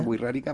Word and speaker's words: Wixárika: La página del Consejo Regional Wirrárika, W-I-R-R-A-R-I-K-A, Wixárika. Wixárika. Se pronuncia Wixárika, pero es Wixárika con K.